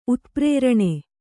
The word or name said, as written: ಉತ್ಪ್ರೇರಣೆ